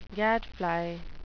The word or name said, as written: gadfly